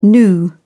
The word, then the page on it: gnu: British English